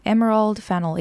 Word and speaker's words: Emerald Fennell: Wait, how do you pronounce her name? Emerald Fennell, yeah.